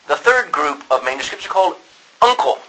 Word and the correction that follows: uncial: No. Unical, or U-N-I-C-I. Is it I-A-L? Yeah. U-N. I'm not. U-N-C-I-A-L. Uncial. That's how you pronounce it.